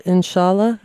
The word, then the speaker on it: Inshallah: There's a song on the album too that I read about called Inshallah.